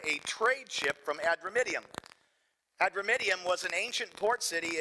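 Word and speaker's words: Adramyttium: And embarking in a ship of Adramidium, is how it's pronounced. Cameron nailed it. Adramidium,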